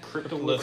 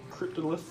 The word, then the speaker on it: Cryptolith: Cryptolith. – Cryptolith.